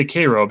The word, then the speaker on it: Cairo: There's also a, you can visit the USS Cairo, and I say Cairo because I believe it was named after the city in Illinois, which is pronounced Cairo, mm-hmm.